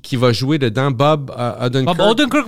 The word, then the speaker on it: Odenkirk: Fait que ajoute Bob Odenkirk, c'est ça comme. – Odenkirk, ouais.